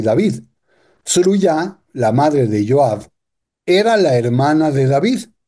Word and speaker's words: Joab: De hecho, Joab, que se escribe en el mundo latino y se pronuncia como Joab,